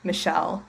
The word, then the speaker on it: Michelle: So it's my name, Michelle, with two L's because it's spelled right.